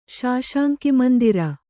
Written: ಶಾಶಾಂಕಿಮಂದಿರ

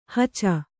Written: ಹಚ

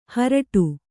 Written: ಹಾರಾಡು

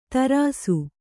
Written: ಧೀರೈಸು

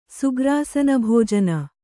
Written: ಸುಗ್ರಾಸನಭೋಜನ

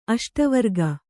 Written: ಅಷ್ಟವರ್ಗ

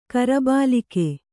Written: ಕರಬಾಲಿಕೆ

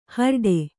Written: ಹಾರಾಡು

